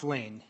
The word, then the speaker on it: Lahn: Is it Mr. Lon? – Yeah, it's pronounced Lane. – Lane, Mr. Yep. Lane, if you could state your name and who you represent for the record, please, sir. – Yes, thank you. It's Chris Lane, Northern Township Administrator. The last name is spelled L-A-H-N.